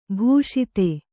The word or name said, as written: ಭೂಷಿತ